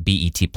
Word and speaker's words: BET: Paramount is shutting down the Bet Plus streaming service, or BET. – Oh, yeah. I have heard of that streaming service. – I think I've heard of it. Am I saying it right? – BET. – It's not Bet.